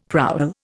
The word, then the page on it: prowl: Indian